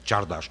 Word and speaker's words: Csárdás: Csárdás.